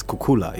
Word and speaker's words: Kukula: Did I get the pronunciation right? – As in Kukula Capital? – Yes. – It's Kukula, yeah.